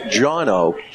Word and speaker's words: Johnno: Is pronounced Johnno. J-O-H-N-N-O.